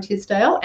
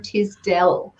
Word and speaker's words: Tisdell: Everybody calls me Karen Tisdale, and it is Karen Tisdell.